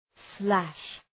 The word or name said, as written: slash